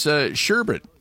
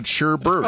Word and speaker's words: sherbet: Okay, and it's sherbet, not sherbert. – Oh, that's huge. – Yeah, sherbet. That one yeah.